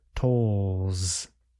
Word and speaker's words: tolls: Now we will say the plural noun, the noun you heard in the report. Tolls. Tolls. Tolls.